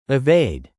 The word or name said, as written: evade